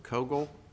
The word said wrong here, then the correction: Cagle: do I have that right? – It's Cagle, Your Honor. – Cagle, sorry.